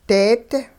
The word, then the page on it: deedi: hupa